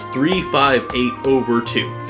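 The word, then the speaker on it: 358/2: Some people have said divided by 2. It's over 2. That's the way you're supposed to say it, and I know I don't say it correctly. I like to say like the whole number. The way you're supposed to say it is 358 over 2. That's the way that Nomura has said. That's how you pronounce the title.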